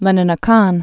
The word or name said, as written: Leninakan